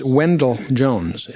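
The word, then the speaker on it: Vendyl Jones: His name is Wendell Jones. It's spelled V-E-N-D-Y-L, Jones.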